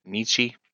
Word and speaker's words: Nietzsche: Friedrich Nietzsche,